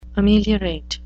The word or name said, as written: ameliorate